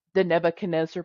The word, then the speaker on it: Nebuchadnezzar: Rick, you want to scroll down to the Nebuchadnezzar part? – Oh, yeah.